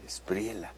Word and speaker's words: Espriella: Bueno, además será el acto de estreno del gobernador, del nuevo presidente de Colombia, el presidente de la Espriella, ¿va? Se pronuncia con la doble L, quien apenas el viernes, me parece, asumió la presidencia de Colombia.